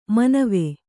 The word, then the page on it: ಮನವೆ: Kannada